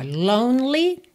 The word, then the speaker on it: lonely: Então, ó, lonely, não pronuncio o E do meio. People, sempre importante verificar a pronúncia dessa palavra, né? Que se escreve P-E-O-P-L-E.